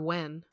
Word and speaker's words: Nguyen: The N-G-U-Y-E-N. – Oh, I thought it was pronounced like Nguyen or Wen. – Oh, yeah. I think that's how you say it.